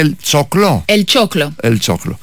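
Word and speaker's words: El Choclo: Αυτό είναι, πώς προφέρεται, Ελ Τσόκλο. – Και Άστορ Πιασόλα. – Σωστότατα. – Μισό λεπτό. Το άκουσα να το λέει. Εγώ τον έλεγα Πιατσόλα τόσο καιρό.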